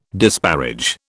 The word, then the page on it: disparage: British English